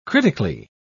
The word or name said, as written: critically